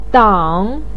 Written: dang3

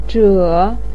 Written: zhe3

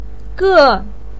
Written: ge4

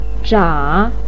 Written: zha3

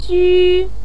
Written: ju1

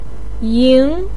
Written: ying2

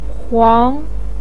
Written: huang2

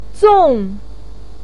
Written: zong4

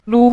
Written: lu5

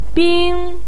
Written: bing1

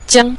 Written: jiang5

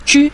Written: ju5

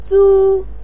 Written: zu1

